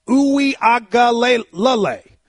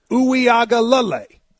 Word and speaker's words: Uiagalelei: DJ Uiagalelei. Uiagalelei. Uiagalelei. So when you watch the game, Boston College. – Yeah. – Uiagalelei. Very few last names have six syllables.